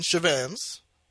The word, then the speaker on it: Chevans: And because Jamaicans aren't necessarily the best with pronunciation, we just started saying Chevans. So, like, Che and Vans, like the Vans out on the road, or like the Vans shoes brand.